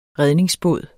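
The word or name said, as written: REDNINGSBÅD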